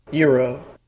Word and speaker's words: gyro: I said I would like the gyro. I would like the lamb gyro. – Uh-huh. – And they just started laughing at me. It's pronounced euro.